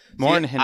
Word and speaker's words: Mornhinweg: Here's what offensive coordinator Marty Mornhinweg, Morinwig, Mor- Morhinwig.